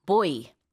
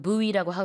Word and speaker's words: buoy: boy. – Boy. – 이건 그래서 헷갈릴 수 있어요. 그냥 남자 아이랑 이제 발음이 같으니까. 근데 boy라고 하기도 하고 buoy라고 하기도 하는데 스펠링은 buoy고요.